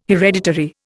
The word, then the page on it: hereditary: Indian